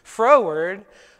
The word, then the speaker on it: froward: I didn't mispronounce Ford. It's froward, which is a song about a heart that is willfully contrary to the Lord.